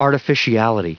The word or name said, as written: artificiality